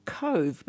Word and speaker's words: Cobh: Cutting across to Ireland now, a place called Cove, but it's spelt COBH.